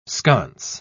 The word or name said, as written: sconce